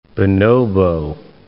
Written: bonobo

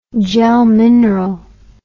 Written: gelmineral

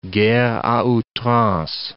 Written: guerre a outrance

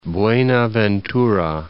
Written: Buenaventura

